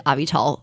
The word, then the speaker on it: Avital: I sort of tell people it's a combination of Advil and Tylenol, but it's spelled like Avital, like a vital organ or a vital source of information. So Avital.